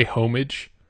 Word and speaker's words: homage: So, yeah, I watched this trailer, and my impressions on it were it's definitely playing homage to the original, for sure. – Wait, did you just say homage? – Did I misuse the word? – It's homage. – Homage? – The H is silent.